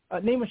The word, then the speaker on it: Nima: N-I-M-A, or, wait a minute, I'm saying it right, hold up, Nima. Nima, shout your Instagram out for us to make sure I don't get it wrong.